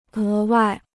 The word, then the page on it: 额外: é wài